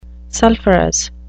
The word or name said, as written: sulfurous